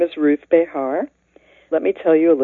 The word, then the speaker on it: Behar: But we don't pronounce it that way in English. We just say Behar, most of us. Some people say Behar, and some people say Behar. But I like Behar. It's very easy to spell. Just write bear, you know, like grizzly bear, B-E-A-R.